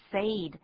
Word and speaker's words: said: 请注意 said 的发音 ,S-A-I-D. 你看到这个 A-I, 可能会想要把它念成 said, 可是这是错的，这是例外字。 AI 通常念 A，可是在这里要念作 E。